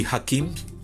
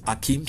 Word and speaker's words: Hakim: Hakim, o sea, Yalda se escribe con Y, como suena, Yalda, y Hakim o Akim se escribe con H, H-A-K-I-M.